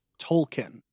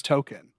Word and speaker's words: Tolkien: What they did was they retconned it, and his character is pronounced Tolkien. – Oh, my God. – As in named after J.R.R. Tolkien. And they made it like, why would you think that my name was Token?